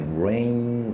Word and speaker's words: rain: Rain，OK，Rain，我会如果拖长一点来念是 Rain，Rain，In，我后面有这个 In 这个音，所以其实我们在母音的时候不是念 Ran，Ran 的话就是同一个母音嘛，就是它的发音模式是一样的，就是同一个母音。但是如果我们念 Rain 的话，其实它有中间有个转换的部分，这个就是我称之为转音了，OK，好。